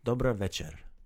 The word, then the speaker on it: Dobra večer: Dobra večer. – Dobra večer. – Very nice. So instead of dobar, it's dobra, and večer.